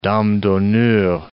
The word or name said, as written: dame d'honneur